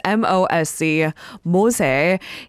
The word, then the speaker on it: MOSE: Now, if you know how to pronounce this project in a more Italian accent, it makes more sense, the naming of it all. The acronym is MOSE, MOSE.